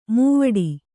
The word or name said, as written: ಮೈವಡೆ